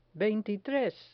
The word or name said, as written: veintitres